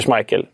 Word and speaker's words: Schmeichel: Och det är ju Peter Schmeichel eller Peter Schmeichel, med hur man nu vill uttala det. Båda eh, uttalen finns ju.